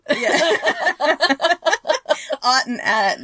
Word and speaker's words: Att: Especially if it's spelled the same, like an A-T-T, yeah, and I would O-T-T, say, and it's just pronounced differently. – It's just pronounced differently. So Mary Ott, it's actually A-T-T, but it's a long A, an Ott. And then in Shuttle Bay 2 is Lieutenant High At, and it's spelled the same way, but she pronounces her last name with the short A, At.